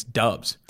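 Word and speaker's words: Doubs: I found you might have to move up our boy Romeo up the board because I found out how to pronounce his last name. I guess it's Dubs.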